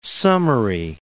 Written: summary

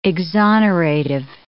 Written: exonerative

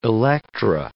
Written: Electra

